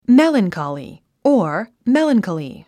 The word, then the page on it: melancholy: mélənkὰli; メランカリィ